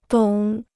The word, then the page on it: 懂: dǒng